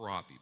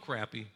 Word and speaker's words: crappie: I guess you're supposed to call it a crappie, but it's spelled crappy, so I call it a crappy.